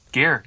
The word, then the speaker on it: Gare: Second guy, Danny Gar, not sure if that's really how you pronounce it correctly. Boy, yeah. – Gare, yeah, let's go with Gare.